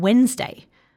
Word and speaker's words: Wednesday: The list is made up of really common but tricky words like any and Wednesday. All of them have something a little bit sneaky about their spelling, like the unusual pronunciation of the letter A in any or the silent D in Wednesday.